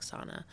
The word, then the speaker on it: SSANA: So that's the Syringe Service Alliance of the Nashua Area, SSANA, but we say it like SANA, just because it rolls off the tongue nicely.